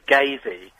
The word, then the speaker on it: Gazey: I've never known really how you pronounce it. Is it Gazey or Gazey? But it is Gazey.